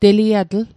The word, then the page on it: teliatl: Mi'gmaq